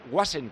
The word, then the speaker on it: Goazen: Escribir se escribe Goazen, ¿no? – Goazen, sí. Goazen, pero se pronuncia Guasen, como dicen. – Guasen, sí, las Z se pronuncian S.